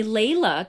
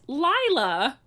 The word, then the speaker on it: Laila: I'm going to jump in and just take this moment to say, everyone calls me Layla because that's how it's spelled, but it's actually pronounced Lila.